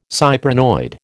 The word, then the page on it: cyprinoid: American English